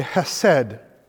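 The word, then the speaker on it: chesed: There is this word in Hebrew, and I'm not going to say it right because I can't do that CH sound. So we break it down in English to say hesed.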